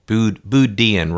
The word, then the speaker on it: Boo Deen: Boo Deen rhymes with rude European.